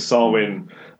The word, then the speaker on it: Samhain: I'm going to try and pronounce this right, Samhain. It's sort of spelled Samhain, it's a Gaelic word, but Samhain.